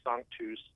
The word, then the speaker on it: Sanctus: The proper pronunciation, I've got to acknowledge it. It's actually Sanctus. And it's Latin for holy.